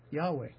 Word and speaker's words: Yahweh: His name is Yahweh. That's one pronunciation of Jehovah, Yahweh.